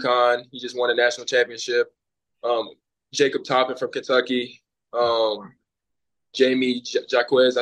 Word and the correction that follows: Jaime Jaquez: Jaime Jaquez.